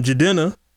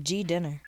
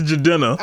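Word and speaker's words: Jidenna: It sounds like G Dinner. – No, it's Jadenna. – I'm just saying, it just reminds me of G Dinner. – G Dinner. It's Jadenna.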